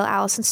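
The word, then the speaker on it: Alyson: If you just YouTube my name, you'll find my channel, The Real Allison Stoner.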